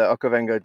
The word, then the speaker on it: Okavango: Okay, so first of all, this was shot in the Okavango Delta. Am I saying that right? – Yeah, the Okavango Delta, that's correct, in northern Botswana.